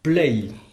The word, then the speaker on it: plej: Alors cette fois-ci, nous sommes passés du comparatif pli ol au superlatif play. Play, ça s'écrit P-L-E-J. Play.